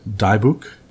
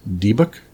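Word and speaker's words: dybbuk: It's a pronounced, I pronounce it a Dibuk, but I've heard it pronounced Dibuk and Dibuk as well.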